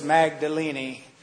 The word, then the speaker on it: Magdalene: And he was speaking uh, in, in, the, in the lecture that he was giving, and he referred to Mary Magdalene. I said, I'm going to pronounce it that way from now on. Come what may. I mean, what's, who, what Anglican clergyman does not have at least a few affectations? Right? so it's Magdalene.